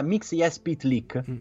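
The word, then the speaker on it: Mxyzptlk: Sì, ma fondamentalmente si pronuncia, almeno dopo hanno fornito l'esempio, è molto simile alla pronuncia straniera fatta da un giapponese. Cioè ci metti ogni tanto qualche vocale in mezzo per riuscire a pronunciare. Infatti diventa Mixias Pytlik.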